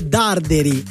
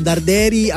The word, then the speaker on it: Darderi: Ringrazio Michele da Andrea che mi fa notare che l'accento è sulla A e non sulla, quindi è Darderi e non Darderi.